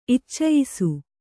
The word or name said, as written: ಇಚ್ಚಯಿಸು